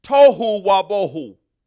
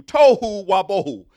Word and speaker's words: tohu wa bohu: But the word void is bohu, B-O-H-O-O. Somebody say boho. So it's tohu and bohu. That's how you pronounce it. So in the Hebrew, it would be pronounced tohu wa bohu. That's how you would say those two words in Hebrew, tohu wa bohu.